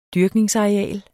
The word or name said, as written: dyrkning